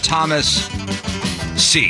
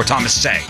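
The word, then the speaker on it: Thomas Seay: And we'll meet 30-year-old Thomas C. Is that how we say his name? Or Thomas Say? – I think it's Thomas Say. – All right, Thomas Say. S-E-A-Y.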